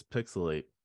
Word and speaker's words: PixelHate: This is Pixelate, where the H is silent, and the hate is real, more so than usual, especially for a good amount of us here today.